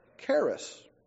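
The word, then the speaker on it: charis (χάρις): The New Testament Greek word, typically translated as grace, is pronounced charis, C-H-A-R-I-S.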